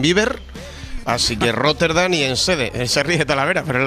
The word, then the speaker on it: Enschede: ¿En Schede puede ser, Romero? En Países Bajos, Antonio Romero. Hola, Antonio. – Perfecto. ¿Lo he dicho bien? Sí, lo has dicho muy bien. Joder, en Schede.